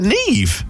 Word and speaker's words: Niamh: Is that Niamh? – Yeah. – Or N-I-A-M-H? – Yeah, pronounce Niamh.